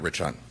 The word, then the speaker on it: Rich Hunt: Lieutenant Rich Hunt. – Can you spell it? – R-I-C-H-H-U-N-T.